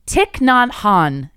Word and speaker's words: Thich Nhat Hanh: Thich Nhat Hanh is how I'm going to say this is pronounced based off the average pronunciation on this video.